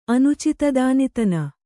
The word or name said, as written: ಅನುಚಿತದಾನಿತನ